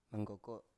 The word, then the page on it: manggoko: Biak